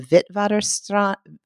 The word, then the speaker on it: Witwatersrand: I would say of the Witwatersrand in South Africa.